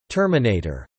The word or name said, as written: Terminator